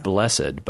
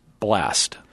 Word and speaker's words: blessed: And so and you can see an example of that shift between a pronounced and a silent E in the word blessed. Because when we use it in religious context, we pronounce the E blessed. But when we do it more informally, it's blessed.